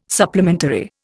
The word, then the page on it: supplementary: Indian